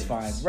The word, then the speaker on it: Fiennes: Is it Fiennes? Ralph Fiennes? – I don't know. – But it's spelled finesse. – Like, yeah. Yeah. But I always, because I thought I heard Fiennes, and maybe it is Fiennes. Ralph Fiennes. Fine, I'll say it. Ralph Fiennes.